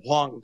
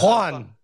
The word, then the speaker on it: Juan: Yeah, I was telling her about your pronunciation of Juan, and I said Wong Kong. – No, it's Juan.